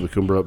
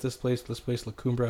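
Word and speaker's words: La Cumbre: And I was like, oh, yeah, this place, La Cumbra. I'm like, yeah, I'm pronouncing the E like you do in Brulee.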